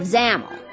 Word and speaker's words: Zamel: Well, technically, we landed in the kingdom of Zamel. Rhymes with camel.